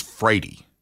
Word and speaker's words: Wrede: And, you know, when I was, my last name is Frady. – I know. – Now, the way you actually spell my last name is W-R-E-D-E.